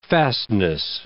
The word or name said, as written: fastness